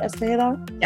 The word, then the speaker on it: Escajeda: Did I say that right, Escajeda? – Yeah, that's good, yeah.